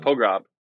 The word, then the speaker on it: Pogrob: Like, how do you say it? Like, I want to get Pogrob. Right, anyway. Pogrob.